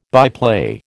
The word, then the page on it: byplay: American English